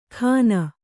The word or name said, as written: ಖಾನ